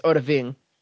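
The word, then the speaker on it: Odahviing: It's Oda Ving.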